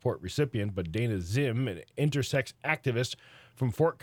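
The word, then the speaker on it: Zzyym: Zim, pronounced Zim, because it's Z-Z-Y-Y-M. – A double Z. – Double Z, double Y-M.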